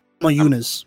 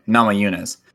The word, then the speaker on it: Namajunas: Is that or? – Namajunas. – What'd you say? Namajunas? – Yeah. – Okay. Namajunas.